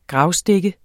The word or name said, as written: gravstik